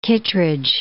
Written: Kittredge

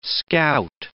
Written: scout

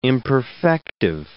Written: imperfective

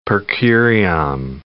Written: per curiam

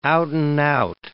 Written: out-and-out

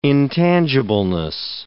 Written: intangibleness

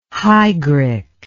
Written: hygric

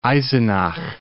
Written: Eisenach

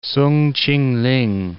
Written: Soong Ch'ing-ling

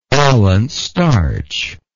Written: alant starch

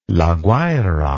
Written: La Guaira

